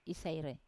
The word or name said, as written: isaire